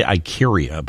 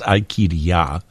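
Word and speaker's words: Ikaria: Some people say Ikiria, but I think the correct way to say it is Ikiria.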